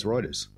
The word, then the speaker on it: Reuters: Everybody thought it was Reuters. – It's Reuters, isn't it? – Yeah, it's Reuters.